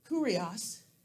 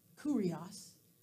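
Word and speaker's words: kurios (κύριος): The Greek word, kurios, and it's spelled K-Y-R-I-O-S. K-Y-R-I-O-S, kurios. This is the Greek translation for the word Yahweh.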